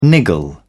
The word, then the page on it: niggle: British English